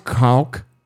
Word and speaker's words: Kauk: Now, a gentleman by the name of Ron Koch, I told you there's going to be some Koch talk. – Cool. – It's pronounced Kauk. – Kauk? – Kauk. – This Ron is C-A-U-C-K? – K-A-U-K.